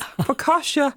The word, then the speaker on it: focaccia: There's focaccia with roasted red onions.